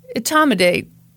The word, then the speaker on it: etomidate: It's called etomidate. – I don't think that's how it's pronounced. – Etomidate.